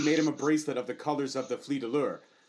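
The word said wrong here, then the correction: fleur-de-lis: And Fleur de Lis.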